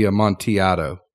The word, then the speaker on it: Amontillado: I know, but it would be Amontillado. – I like Amontillado.